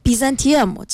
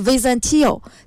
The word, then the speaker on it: Byzantium: 因为它的这个英文的读音其实是 Byzantium，其实是 Byzantium 是这个音，就是英文里面叫拜占庭。但是在以前的希腊语里面的时候，这个拜占庭的读音是 Byzantio，其实还是更加偏于这个拜占斯的这个名字的。